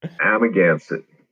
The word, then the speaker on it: Amagansett: Well, before we get to, and I have to clarify pronunciation, I should have done this before we started recording, but Amagansett, am I saying that right? – Absolutely not. – Okay. – Amagansett. – Amagansett, okay. – Amagansett. It's exactly how it is spelled, and it's not how you would think. It's a Native American word from the Montaukett Nation.